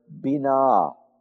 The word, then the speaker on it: binah: The word translated here as discernment is the word bina. You can write that phonetically as B-I-N-A-H. Bina.